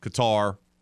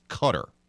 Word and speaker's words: Qatar: By the way, just so everyone knows, it's not Qatar, it's Qatar is how you pronounce it. I know that's confusing, because the way it's spelled, there's an A in there, there's two of them. Q-A-T-A-R, but it's pronounced Qatar.